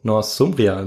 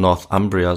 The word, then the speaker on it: Northumbria: Northumbria überfielen. Weiß nicht, ob ich es richtig ausgesprochen habe. – Äh, wahrscheinlich würde man Northumbria sagen auf Englisch, ja.